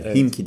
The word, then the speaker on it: Himki: Anadolu Efes'te önce Alba Berlin'i 84-76, ardından da Himki diye okunuyor.